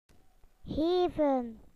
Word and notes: to give: British English

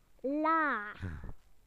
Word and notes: low: British English

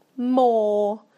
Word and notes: more: British English

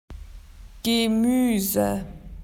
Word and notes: vegetables: Australian English

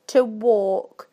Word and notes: to walk: British English

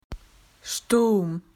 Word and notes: storm: Australian English

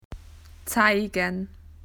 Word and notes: to show: Australian English

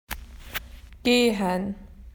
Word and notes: to walk: Australian English

